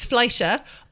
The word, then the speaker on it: Fleischer: Specifically, when a woman called Helen Kane filed a $250,000 lawsuit against a man called Max Flesher. I may not be pronouncing his name correctly. Flesher? Fleischer? Flesher. – Is there an I? – F-L-E-I-S-C-H-E-R. – Fleischer. Yes, this is American, isn't it? – It's American.